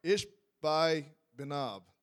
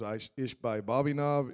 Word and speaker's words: Ishbi-Benob: And Ishbi-Benob, I didn't say it right. Ishbi-Benob, there we go. One time I preached this, it was Ishbai Bobby Knob, Ishbai.